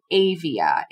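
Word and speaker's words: Aavia: So the app is called Avia. It's A-A-V-I-A. I think that's how you pronounce it, I-D-K.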